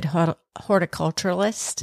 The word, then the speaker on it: horticulturalist: So we actually aren't very good horticulturalists. – That's right. – Did I say it right? – Horticulturalist. It's a hard word to say.